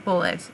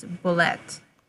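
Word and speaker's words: bulette: It's not bullet. It's bullet. – Yeah. Now, I will admit, I have been calling it bullet my entire adult life. So... – Apparently it's the French pronunciation. – Yeah, I mean, it does have the extra E at the end.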